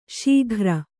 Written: ಸಗ್ಗಾರ

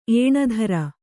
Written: ಏಣಧರ

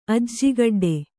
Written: ಅಜ್ಜಿಗಡ್ಡೆ

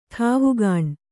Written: ಠಾವುಗಾಣ್

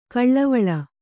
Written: ಕಾಲುವಲೆ

